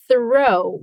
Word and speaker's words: throw: Watch out for the pronunciation. Throw with a TH.